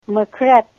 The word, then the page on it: makret: Ninilchik Russian